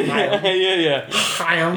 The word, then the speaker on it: Haim: But yeah, uh, Haim or Haim or whatever. How's it? Uh, it rhymes with rhyme, so Haim.